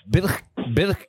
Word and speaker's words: Birkebeiner: Yeah, how do you say it again? – Birkebeiner. – Birkebeiner. – Birkebeiner. Yeah. – Oh, you roll the R. – Yeah, we roll the R a lot here.